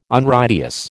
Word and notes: Unrighteous: American English

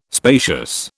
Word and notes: spacious: British English

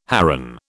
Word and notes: heron: British English

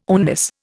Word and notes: oneness: Indian

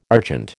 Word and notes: argent: American English

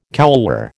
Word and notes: couloir: American English

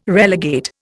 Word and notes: relegate: Indian

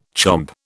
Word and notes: chomp: British English

